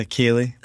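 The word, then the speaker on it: ceilidh: So you explain to someone who has no idea what you're talking about, what is a Kayleigh? – So how do you spell it? Because it's not Kayleigh. The way it sounds.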